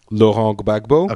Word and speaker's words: Laurent Gbagbo: D'Ivoire, Laurent Gbagbo. – Okay, yeah, that's the French pronunciation.